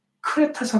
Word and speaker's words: 크레타: 지금 오늘날은 크레타 섬이라고 그래요. 한국 발음으로 크레타 섬이라는 부분이 여기 이제 조그맣게 그 밑에 있는데 이제 이 니도라는 데서 그레데라는 것에서 그레데에서 이제 이탈리아로 이렇게 이제 관련을 하고는 있는 여정입니다.